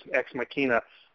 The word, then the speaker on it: Ex Machina: And thank you for correcting my pronunciation. It is Ex Machina.